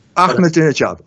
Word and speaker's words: Ahmadinejad: Please give my respects and my regards to Mr. Aminehadab. I'm sorry, I can't get the name right. – Ahmadinejad, I will.